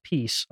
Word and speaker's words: PiS: People would keep saying it's pronounced peace. I don't care, that still sounds like piss. Like in English, that's what it sounds like.